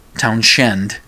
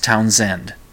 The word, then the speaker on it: Townshend: He kept saying Townshend when it's Townsend. Pete Townshend. That's his name. It's not, the H is silent.